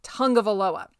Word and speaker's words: Tagovailoa: I assure you, he gave us a whole dissertation on it with the Miami media, but you pronounce it Tagovailoa. That's how you say it. I guess it's a Hawaiian thing.